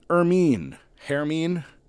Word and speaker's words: Hermine: Also from that season, Hermine. I know we pronounce the H's in Britain.